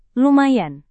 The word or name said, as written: lumayan